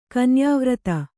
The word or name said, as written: ಕನ್ಯಾವ್ರತ